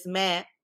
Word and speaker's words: Meh: So in the second film that you probably seen, which is Meh, like pronounced like the bleating of a goat.